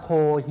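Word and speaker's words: โ ค ฮี (コーヒー): ทคโฮมนะครับเทคเอาท์ไปกินที่บ้านนะครับหรือนำออกไปกินที่ข้างนอกนะครับอย่างเช่นเราไปซื้อกาแฟที่ร้านนะครับแล้วก็จะเอานำกลับไปกินที่บ้านนะครับจะมีคำอะไรบ้างที่น่าสนใจนะครับเริ่มจากตรงหัวข้อก่อนนะครับมีคำว่าโคฮี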